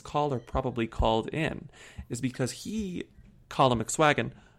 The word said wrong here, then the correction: McSwiggin: You said it wrong. – Colin McSwiggin.